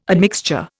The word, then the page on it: admixture: Indian